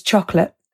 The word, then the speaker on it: chocolat: So one of them was chocolate, and they used cliché to remind them that CH was sh. We'd already done some stuff on silent letters at the ends of words, so they were all able to say chocolat without them hearing it from me first.